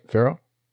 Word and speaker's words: Ferro: Am I saying that right, Ferro? – Yeah, Ferro.